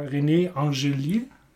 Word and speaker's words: René Angélil: Und die musste aber erstmal so überredet werden von ihrem damaligen Mann und Manager René Angélil. Bestimmt richtig ausgesprochen. – Oui. – Genau, sie ist ja Franco-Kanadierin, also das ist im Französischen.